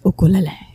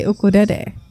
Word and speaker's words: ukulele: And so people will get really passionate about the pronunciation, and they'll say it's pronounced ukulele, you know, like the Hawaiian way. And then I go to Japan and they say ukurere because they don't have the L sound. Uh-huh. It's ukurere, like dede.